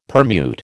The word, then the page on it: permute: American English